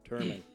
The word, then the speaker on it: Turman: Next fight that we got here, we got middleweight, the mullet, Andrew Sanchez going up against Wellington Truman. Sorry. – Turman. – Is it Turman? – Yeah. – Yeah, it's Turman. – Spell it. – I got it. So I had it right the first time.